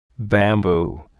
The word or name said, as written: bamboo